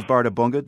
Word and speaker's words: Bárðarbunga: This one is Bárðarbunga. Did I get that correct? – You got that correct.